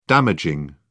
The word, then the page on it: damaging: British English